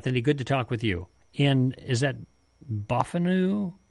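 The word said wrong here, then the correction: Bottineau: North Dakota? – It's Botano. Yeah.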